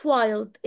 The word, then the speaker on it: wild: اوكي كل الحاجات دي بتحسب long i عشان ال i جا بعدها اما n و d اما l و d. ازاي؟ كلمة wild it's spelled w i L D wild W I L D.